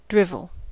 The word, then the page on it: drivel: American English